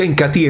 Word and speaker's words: Incatea: Tes el Incati, se escribe Incatea, pero se pronuncia Incati en una forma natural.